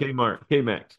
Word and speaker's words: Cammack: Did I pronounce it right? – Cammack, but I answered anything. – Cammack? All right. Yeah, it's like Cammack, but it's with a C.